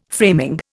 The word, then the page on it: framing: Indian